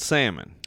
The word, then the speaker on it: Salmon: Spelled salmon. – Okay. – So if you're looking for me online, S-A-L-M-O-N, like the fish, that's how you do it.